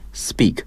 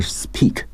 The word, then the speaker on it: speak: 类似的还有 speak，应该读成 speak 而不是 speak，所以大家一定要注意这一点。